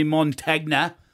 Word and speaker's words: Montagna: Chatting with former St Kilda champion Joey Montagna.